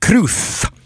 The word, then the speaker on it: cruz: So the word cruz is pronounced cruth.